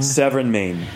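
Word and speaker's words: Sèvre et Maine: So when a bottle says, and help me on my pronunciation, Sèvres et Maine? – Sèvres et Maine, yeah.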